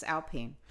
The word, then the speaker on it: Alpine: However, Renault CEO Luca de Meo has said that Formula One will be the cornerstone of the Alpine brand. And by the way, we have confirmed it actually is Alpine. We found this out before the broadcast this weekend. – Yes, it is Alpine.